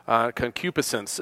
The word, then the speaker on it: concupiscence: Concupiscence is the way you pronounce it.